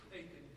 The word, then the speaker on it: Achan: Aiken.